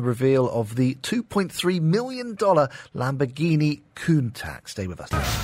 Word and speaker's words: Lamborghini Countach: Lamborghini Countach.